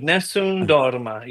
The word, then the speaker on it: Nessun Dorma: Nessun Dorma. Yes. Just got to roll that tongue a little bit. But yeah, absolutely. Nessun Dorma means no one sleeps in Italian.